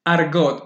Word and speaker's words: Argotte: ¿Cómo se pronuncia tu apellido? – Argot. – Argot. Ok, con ustedes le presento a Bárbara Argot.